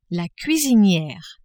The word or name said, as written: cuisiniere